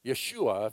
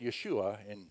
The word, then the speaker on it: Yeshua: Yeshua, if you say his name in Hebrew, that's how you say it, Yeshua in Hebrew.